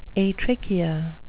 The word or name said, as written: atrichia